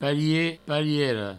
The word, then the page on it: parier, pariera: parie, pariero